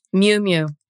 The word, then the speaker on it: Miu Miu: Miu Miu. – Miu Miu.